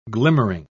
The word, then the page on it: glimmering: British English